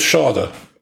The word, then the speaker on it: Schade: The signing of Schade, I believe that's how it's pronounced, rhymes with larder.